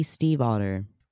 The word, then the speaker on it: Stiefvater: Maggie Steve Otter. – Steve and Otter, even though it's spelled different. – It's spelled very different. Wow. – It is. – It's spelled S-T-I-E-F-V-A-T-E-R.